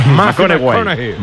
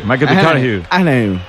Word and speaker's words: McConaughey: Me ha dicho que hay que ir corrigiendo la pronunciación. – McConaughey. McConaughey. Bueno, pues el Matthew se...